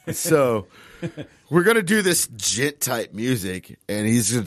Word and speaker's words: djent: It has a J in it, but the J is silent, and it ends with a T. Music, they call it djent.